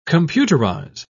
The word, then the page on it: computerise: British English